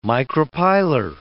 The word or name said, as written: micropylar